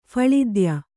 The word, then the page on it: ಫಳಿದ್ಯ: Kannada